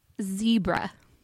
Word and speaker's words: zebra: Zebra. – Ooh, zebra. – Zebra. That's like, it rhymes with Deborah.